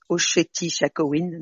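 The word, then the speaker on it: Oceti Sakowin: Alors, c'est le vrai nom de la tribu Sioux qui, j'ai su après l'avoir enregistré qu'en fait, ça se prononce Ossétie Sakhovin.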